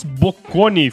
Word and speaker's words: Bocconi: Bocconi.